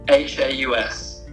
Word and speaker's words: HAUS: Um, it's spelled really awkwardly, uh, so it's Tree uh, forward slash forward slash HAUS, like the German house, uh, which is really, really hard to explain on stage.